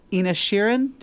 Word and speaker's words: Inisherin: Avatar, The Way of Water, The Banshees of Inisherin.